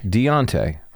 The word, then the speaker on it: Deuntay: D-E-U-N-T-A-Y. – And you pronounce it? – Deontay. – Deontay.